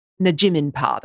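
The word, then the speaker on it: Nampijinpa: And if you see pictures of her, Najiminpar, that's how you say it, obviously Aboriginal.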